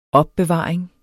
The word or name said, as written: OPBEVARING